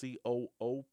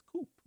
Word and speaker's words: co-op: So if you look at it, it's coop. – Yeah, without the hyphen, you would pronounce it coop. But that's right, co-op. – We intend for it to be co-op.